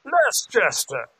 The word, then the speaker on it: Leicester: Certainly he knows that Leicester? – Lester. That's fine. He's Lester. Russian. Lester. He can pronounce it how he wants. – See Lester. – Leicester.